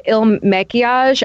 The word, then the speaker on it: Il Makiage: I've actually been loving the Il Makiage, IL, and then like Makiage.